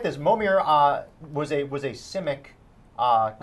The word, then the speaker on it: Momir: And it is going to be the Extra Life Momers Madness. And what's a Momer? – What's a Momer? – Momir. – Oh, I accidentally said it wrong.